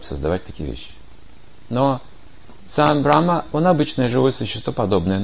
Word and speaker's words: Брахма: Брахма. Брамха, так говорят. Брахма или Брамха произносится. Или еще Рамха. Если вы откроете русский, там написано Рамха. Или Рамхат. Это поклонение Всевышнему называется. Но это Брахма.